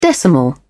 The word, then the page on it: decimal: British English